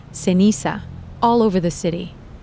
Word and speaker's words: ceniza: And sometimes the C is also pronounced th. So instead of ceniza, you'll hear ceniza.